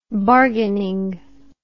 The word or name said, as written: bargaining